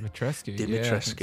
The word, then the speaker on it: Dimitrescu: And strangely enough, the big talk about the game is currently the main antagonist, which is, if I pronounce this right, Lady Dimitrescu. I think that's the correct way of saying it. – Dimitrescu. Yeah, so.